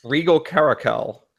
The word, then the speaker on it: Regal Caracal: Regal Caracal. – With the spoiling of Regal Caracal, a better version of Cat Tribal has the potential of showing its rearing its ugly head.